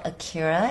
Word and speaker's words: Akira: It's spelled Akira, as A-K-I-R-A. My last name is Lane, L-A-N-E. And, you know, when it comes out, I hope you guys can have the chance to watch it and have a great laugh.